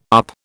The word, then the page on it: up: British English